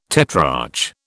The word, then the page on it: tetrarch: British English